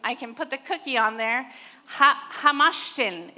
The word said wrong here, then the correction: Hamantaschen: Is Marissa in the room? She knows exactly how to say this word. She's like, oh, hamashtin, or yeah, I don't know. Ham, hama what? Hamintashin. Yes. Say it again. Hamantaschen.